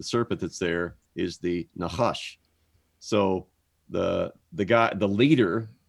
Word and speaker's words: Nahash: So in English, it looks like we would pronounce it Nahash, which I guess we can pronounce it that way. But in the Hebrew, it's Nahash, like you would write it in a C-H. A-S-H.